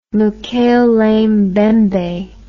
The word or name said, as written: mokele mbembe